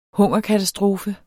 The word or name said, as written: hungerkatastrofe